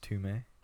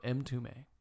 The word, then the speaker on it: Mtume: That's spelled M-T-U-M-E. It's called Tie Me Up. Listen to it. – It's pronounced Tume? – No, M2ME. – M2ME. – M2ME. It's one word. M-T-U-M-E.